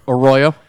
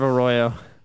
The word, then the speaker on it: Arroyo: Arroyo. – Oh, speak of the devil. It's a ghost of Arroyo. Ha! I said it right that time.